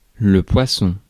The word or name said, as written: poisson